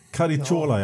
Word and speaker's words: Caracciola: Alltså i det här eh, 31-loppet där när han vann Chiron så eh, Caracciola, heter han så? Uttalar man det lätt? Rudolf. Den eh, klassiska tyske... – Caricciola, ja. ja precis.